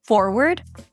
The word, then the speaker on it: forward: Ronnie asked me to explain how to pronounce the words forward and backward. Forward and backward. Now, both words end the same. Listen. Word. Word. Just like the word. Word. Let's say a few words about it. Now, although it's spelled with W-A-R-D, W-A-R-D pronounced as ward, as in the neurological ward.